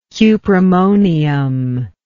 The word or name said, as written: cuprammonium